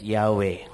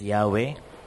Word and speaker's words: Yahweh: We can pronounce the name of God as Yahweh. YHWH, pronounced as Yahweh.